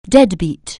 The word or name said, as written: dead beat